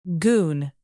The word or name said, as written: goon